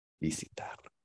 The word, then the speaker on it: visitar: Okay, entonces, muchas veces la gente dice visitar o viajar, pero, y se entiende. Pero técnicamente, ¿cómo pronunciamos esto? – Visitar. – Sí, visitar. Okay, so technically, it's visitar.